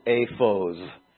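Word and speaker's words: aphos (אפוד): Maybe from then on everybody just, okay, now we know that that's the word ephod. You would pronounce it aphos in Hebrew,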